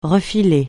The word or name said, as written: refiler